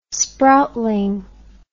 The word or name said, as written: sproutling